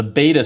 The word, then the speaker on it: b8ta: Another store that opened on the other coast this week, there's a store cleverly or annoyingly, depending on your perspective, named the Beta Store. And it's spelled B, the number 8, T-A.